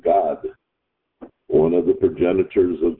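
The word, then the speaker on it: Gad: We pronounce it similar to the English word God, G-O-D.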